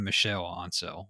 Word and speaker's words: Michel Ancel: Michael Ancel. Apparently you say his name Michel Ancel, whatever.